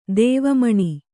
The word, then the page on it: ದೇವಮುನಿ: kannada